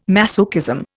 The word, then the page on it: masochism: Indian